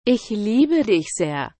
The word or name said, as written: ich liebe dich sehr